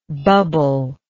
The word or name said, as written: bubblelike